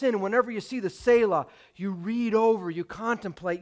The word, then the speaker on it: Selah: There's an interesting word in many of the psalms pronounced Selah.